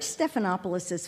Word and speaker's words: Stephanopoulos: This man who taught us to pronounce all five syllables of his name and did so with pride. I've always wondered, who is this unusual fellow? George Stephanopoulos' father is the Greek Orthodox priest and Dean Emeritus of the Greek Orthodox Church in New York City.